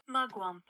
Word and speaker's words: mugwump: Mugwup. M-U-G. Wup. Hold on. Let's see what it says. I hit. There we go. Oh, I got to turn it up. – Mugwump. – How do you say it? – Mugwump.